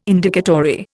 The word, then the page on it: Indicatory: Indian